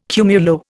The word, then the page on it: cumulo: Indian